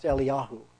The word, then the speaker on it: Eliyahu: And so Elijah here, and again, like I say, in Hebrew, it's pronounced Eliyahu. That's the correct Hebrew way to say that.